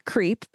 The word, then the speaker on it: CRP: Gordon Liddy, who was the finance counsel for the Committee for the Re-election of the President, also known as CRP, or pronounced CREEP, which is so fitting.